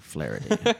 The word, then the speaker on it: Flaherty: I think that I said that correctly. Flaherty. – Sounds right.